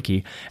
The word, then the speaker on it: Cay: And some of them, if you're going from Florida, are going to make stops at Castaway Cay, or K, depends on how you pronounce it, but they call it Castaway Cay.